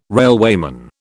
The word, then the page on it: railwayman: British English